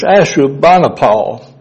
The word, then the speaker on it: Ashurbanipal: But it's Ashurbanipal was the king of Assyria.